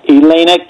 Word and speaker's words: Elena: And he did vote for Sotomayor and Eleni I'm pronouncing her name right, Elena Kagan.